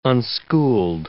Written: unschooled

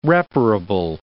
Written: reparable

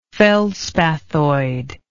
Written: feldspathoid

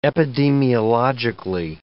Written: epidemiologically

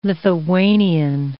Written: Lithuanian

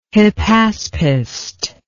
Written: hypaspist

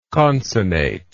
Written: concinnate

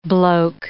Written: bloke

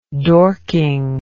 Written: dorking

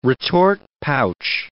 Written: retort pouch

